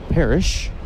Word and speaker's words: Perich: Parrish sends it to the seats down the left field side. 2-2. That's Rafe Parrish, P-E-R-I-C-H. Looks like Parrish. Rafe pronounces it Parrish.